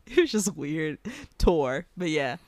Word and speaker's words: Thor: And Thor is actually pronounced Tor.